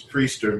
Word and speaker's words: Priester: And I am joined by Chief Justice 06, Will, I can't remember how to pronounce your last name. – It's all good. Last name is Priester, man. – I mean, it's exactly like it's spelled. I should be able to read.